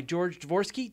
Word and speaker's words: George Dvorsky: Uh, this week, I'm joined by George Dvorsky.